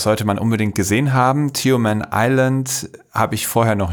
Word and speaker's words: Tioman: Tioman.